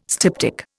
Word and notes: styptic: Indian

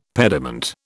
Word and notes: pediment: British English

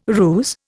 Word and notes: ruse: Indian

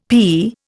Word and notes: pea: Indian

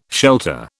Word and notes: shelter: British English

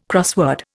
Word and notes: crossword: Indian

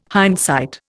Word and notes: hindsight: Indian

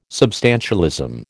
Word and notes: substantialism: American English

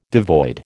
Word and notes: devoid: American English

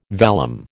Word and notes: vellum: American English

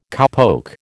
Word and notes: cowpoke: American English

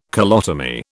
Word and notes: colotomy: British English

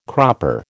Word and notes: cropper: American English

